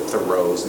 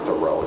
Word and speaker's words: Thoreau: So, you know, everybody in the world pronounces Henry David Thoreau's name in that fashion. Then you're in a class, you're told it's not Thoreau, it's Thoreau.